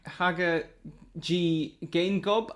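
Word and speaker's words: Hage Geingob: I don't know if I'm pronouncing his name right, but it's Haga G. Gaingob, I think, is the pronunciation.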